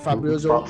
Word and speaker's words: Fabrizio: Fabrizio. I think I'm getting his name right, right? – Fabrizio.